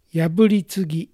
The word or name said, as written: Yaburitsugi